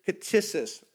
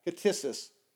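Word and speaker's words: ktisis (κτίσις): the word in the Greek is ktisis. It's spelled like this in basically in the English, K-T-S-I-S, ktisis.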